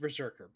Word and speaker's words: BRZRKR: so he has a comic book, co-written with Matt Kind, called, it's pronounced Berserker, but it's BRZRKR, right?